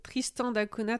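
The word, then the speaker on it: Tristan da Cunha: Tristan Cunha, Tristan Cunha pour les habitants et Tristan d'Acuna pour les Portugais, parce que c'est un navigateur portugais qui a, qui a découvert euh, l'île euh, en 1506.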